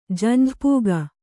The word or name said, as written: ಜಂಝಪೂಗ